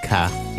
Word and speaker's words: 卡: There's no RD pronunciation. It's just ka.